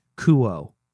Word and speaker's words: Kuo: The phonetic spelling is K-O-O-O-H. Kuo.